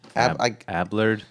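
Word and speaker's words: Abelard: Peter Aberland. Ablard. Peter Ablard. – Ablard.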